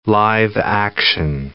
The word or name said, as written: live-action